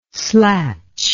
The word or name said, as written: slatch